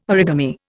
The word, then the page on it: origami: Indian